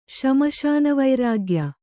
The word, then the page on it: ಶ್ಮಶಾನವೈರಾಗ್ಯ: śmaśana vairāgya